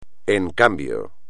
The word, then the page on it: en cambio: espagnol